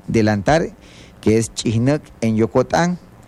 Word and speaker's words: Chihnek: Chihnek.